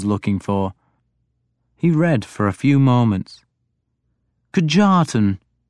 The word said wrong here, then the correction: Kjartan: is that how it is pronounced?" – "Kjartan," I corrected him, making the J sound like a Y.